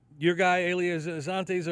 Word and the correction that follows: Israel Adesanya: or I can't pronounce his name. – Israel Adesanya.